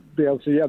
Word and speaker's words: Bielsa: Bielsa, the new Leeds manager.